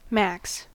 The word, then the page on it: max: American English